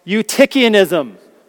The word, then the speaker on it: Eutychianism: This is how I was taught to pronounce this. I'm not quite sure if it's the way all of would have been taught to pronounce this, but it's called Eutychianism.